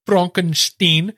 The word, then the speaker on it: Frankenstein: and it also takes a different spin on Frankenstein. Or Frankenstein, if you want to say it like that.